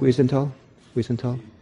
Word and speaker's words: Wiesenthal: And Simon Wiesenthal, is that how you pronounce it? – Wiesenthal.